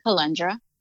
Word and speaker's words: Calundra: And I am on Instagram as well on my personal page under Queen Calundra. And then also my name is spelled CALUNDRA.